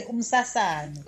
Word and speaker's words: Umsasane: I think Umsasane. A Bordeaux? Yes, it says it's a Bordeaux. – Yes. You just pronounce it nicely. – Umsasane.